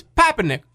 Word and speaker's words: Papernick: But with his New York accent, it sounds so much like Pumpernickel. It's Papernick.